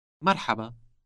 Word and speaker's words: مرحبا: مرحبا. مرحبا. – This word contains that sound pronounced deep in the throat.